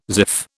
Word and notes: ziff: British English

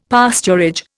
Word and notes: pasturage: Indian

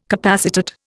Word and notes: capacitate: Indian

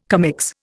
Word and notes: commix: Indian

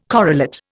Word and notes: correlate: Indian

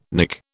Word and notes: Nick: British English

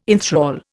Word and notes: enthral: Indian

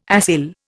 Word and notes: acyl: Indian

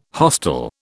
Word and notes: hostel: British English

